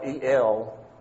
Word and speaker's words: El: The first name is El, spelled E-L, pronounced El, A-I-L, rendered God in the Authorized Version.